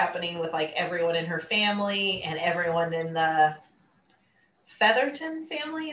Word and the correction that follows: Featherington: Is that what their last name is? – Featherington. – Featherington. Thank you. – Is it? I know there's an extra syllable in there.